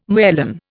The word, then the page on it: merlin: Indian